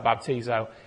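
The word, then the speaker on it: baptizo (βαπτίζω): If you just sort of translate the letters over, we would pronounce that baptizo.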